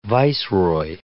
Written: viceroy

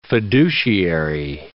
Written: fiduciary

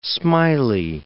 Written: smiley

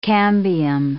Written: cambium

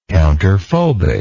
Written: counterphobically

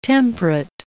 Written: temperate

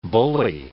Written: bully

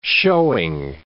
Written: showing

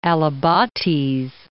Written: Alibates Flint Quarries National Monument

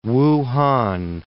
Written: Wuhan

